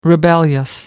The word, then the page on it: rebellious: American English